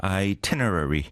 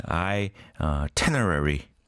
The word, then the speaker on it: itinerary: Speaking of itinerary. 문서로만 보고 입 밖으로 뱉어본 적이 없는 단어. 막연히 마음속으로 이터널러리라고 하고 있었네요. Yes. I think we can share the sentiment. Itinerary.